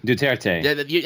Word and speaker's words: Duterte: Is it Duterte? – Duterte.